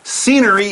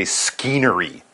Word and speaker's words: scenery: This is a noun, and it's spelled S-C-E-N-E-R-Y. S-C-E-N-E-R-Y. And yes, that is correct. I did say there's a C in there, but we almost don't really say it when we pronounce the word. So it's scenery, but you don't say skeenery. That S-C is just sort of an S sound.